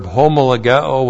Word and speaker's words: homologeo: And then we have the verb homologeo with the negative me. H-O-M-O-L-O-G-E long O. Homologeo.